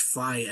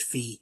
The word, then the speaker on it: Fi: Link's partner in Skyward Sword is actually pronounced Fi and not Fee.